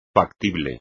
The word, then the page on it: factible: espagnol